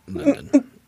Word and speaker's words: LDN: How did Lily Allen pronounce the title of her 2006 hit LDN? – It's London. – Yep, London.